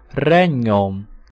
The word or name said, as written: regnum